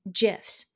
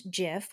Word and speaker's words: GIF: Welcome to today's episode, and I want to talk about creating GIFs. Now, there is great controversy on how you pronounce GIF, which is spelled G-I-F. I believe it is with a J sound.